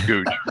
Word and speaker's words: Gouge: He quoted William, I guess it's Gouge. Is that how you pronounce it? G-O-U-G-E. – He said, Gouge is how I say it. – Yeah. – Gouge, I think, is probably the proper way.